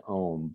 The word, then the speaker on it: EOM: When Eddie had his vision, he changed his name from Eddie Owens Martin to Saint Om, but he spells it E-O-M, but he pronounced it Om.